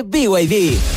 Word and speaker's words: BYD: ¿Los VIP? – ¿VIP? ¿No es VIP? – Nada que ver, se escribe BYD, pero se dice BYD, la marca número uno de electromovilidad en Chile y el mundo.